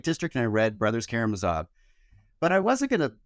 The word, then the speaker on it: Karamazov: And I say I would pick The Brothers Karamazov, which I'm told is actually the way to pronounce it, because it's long and I haven't read it.